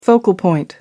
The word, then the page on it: focal point: American English